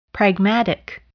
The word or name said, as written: pragmatic